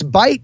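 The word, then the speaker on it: Bight: And yeah, it's Bite, but it's spelled like light with a B.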